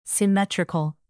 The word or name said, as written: symmetrical